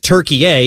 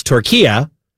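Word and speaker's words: Türkiye: We just go with it because, again, much like last year or last hour, I learned that it's not Turkey-A, it's Turkey-A, which is the correct way to pronounce what we have been calling Turkey, the country, for a number of years.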